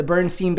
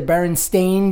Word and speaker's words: Berenstain: Well, do you know it's actually the Berenstain Bears? – Berenstain Bears? – Yeah, it's spelled Berenstain Bears. – Berenstain. – Berenstain. – Huh. – Not E-I-N, A-I-N.